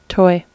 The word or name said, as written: toy